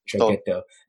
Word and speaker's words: traghetto: Traghetto.